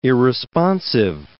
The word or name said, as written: irresponsive